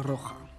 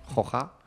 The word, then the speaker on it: Roja: Marcos Alonso va imiter son père et son grand-père qui ont tous deux porté le maillot de la Roja. Je le dis bien? – De la Roja. – Roja. – Voilà.